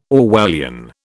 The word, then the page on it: Orwellian: British English